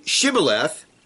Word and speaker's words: Shibboleth: After they would say no, they would then, they were asked to say Shibboleth, which, by the way, is the word for a stream. And Ephraimites were known for pronouncing the SH, shin, like an S, samek.